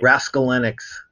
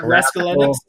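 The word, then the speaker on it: Raskulinecz: How do you say his last name? – I can never say it right. – Raskolnik. – Is that how you say it? – Yeah, not like Raskolnik.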